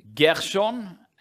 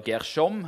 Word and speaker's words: Gershon: Gershon, eller Gershom eh, Bibelen klarer ikke helt å bli enige med seg sjøl hvordan dette navnet skal uttales.